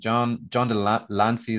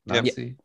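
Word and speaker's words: John de Lancie: I think John DeLancey, is that how you pronounce it? – Lancey? Yep.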